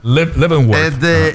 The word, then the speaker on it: Leavenworth: Hay que volar a Seattle y llegas a Leavenworth, que es navideño, aunque sea verano. Perdóname, ahora sigue. – No. Déjame apuntar Leavenworth. Qué bien lo pronuncias. – Leavenworth.